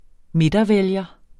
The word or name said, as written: midtervælger